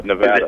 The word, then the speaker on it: Nevada: Nevada.